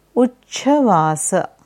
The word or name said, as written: Uchchvasa